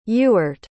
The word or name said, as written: Ewert